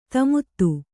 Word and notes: ದಮಥ: damatha

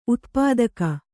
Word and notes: ಉತ್ಪಾತಕ: kannada

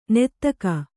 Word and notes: ನೀತಿಕೆ: nītike

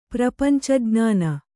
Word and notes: ಪ್ರಪಂಚಜ್ಞಾನ: Kannada

